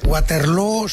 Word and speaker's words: Waterloo: Waterloo se pronuncia igual que Feijóo.